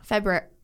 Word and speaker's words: February: February. – But it's really spelled February.